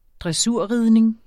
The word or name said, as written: dressurridning